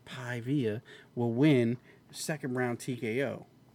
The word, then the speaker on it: Paiva: It's not Via. But it's P-A-I-V-A. – I-V-A. – P-A-I-V-A. Pi-via. – Yeah. So I'm gonna go with him by decision. Fuck you. Okay. Piva.